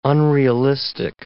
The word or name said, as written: unrealistic